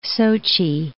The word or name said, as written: Sochi